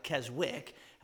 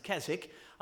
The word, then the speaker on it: Keswick: Well, a man up in the Lake District of England, in a town called Keswick, it looks like Keswick, that's pronounced Keswick, he pastored up there, and he had been to some of the other conventions and was deeply blessed.